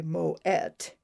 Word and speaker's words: Moët: Moët, you pronounce the T, by the way, Moët, now owns the abbey at Hautvieille, as well as the vineyards that were once managed by Dom Pérignon.